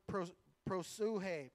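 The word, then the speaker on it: prosuhe (προσευχή): So the word prayer here in this verse is the word prosuhe. Now I know it looks like something else, but that's how you pronounce it.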